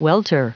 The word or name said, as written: welter